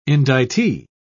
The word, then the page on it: indictee: British English